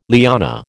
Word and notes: liana: American English

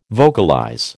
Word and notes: vocalize: American English

